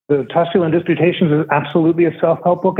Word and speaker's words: Tusculan Disputations: You generously read one of the sonnets, and it was from the Tusculan Disputations. Do I have that pronounced correctly? The Tusculan Disputations? – Yeah, absolutely.